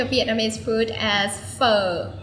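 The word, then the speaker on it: phở: No, pho. It's like a question mark. Question mark on top of the O. – Pho. – Pho. Like you bend your voice and you drop it very quick.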